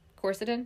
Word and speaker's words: Quercetin: Quercetin? Is that how you say it? Anyways, so it sounds right.